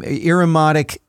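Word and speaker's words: Irumodic: irimotic syndrome.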